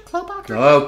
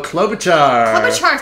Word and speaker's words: Klobuchar: Is it Klobuchar? – Klobuchar. – Klobuchar.